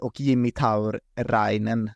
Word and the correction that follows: Tauriainen: då, Tauriainen, förlåt alla finska lyssnare, eh, Thomas Engstrand då, bland annat, eh, på hur det uttalas.